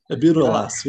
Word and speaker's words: Ibudilast: but Ibudilast, Ibudilast, yes.